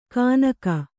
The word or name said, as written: ಕಾನಕ